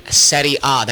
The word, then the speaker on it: Serie A: But I also mentioned that I like another team in the Italian Serie A. That means Series A. – Oh. – That's how you say it in Italian.